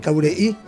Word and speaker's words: Cabureí: Es Adriano Mater, de la cooperativa Cabureí, pronunciado así porque es con acento en la I final, Cabureí, de Misiones, de Andresito.